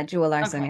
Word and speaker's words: Jewell-Larsen: Jewel Larson.